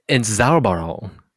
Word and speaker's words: Entzauberung: It's called Entzauberung.